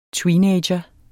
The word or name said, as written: tweenager